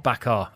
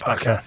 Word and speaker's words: Bakker: It's Bakker, not Bakar. I do apologise, Bakker.